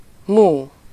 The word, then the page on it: mú: /ˈmuː/